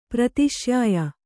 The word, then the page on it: ಪ್ರತಿಶ್ಯಾಯ: prati śyāya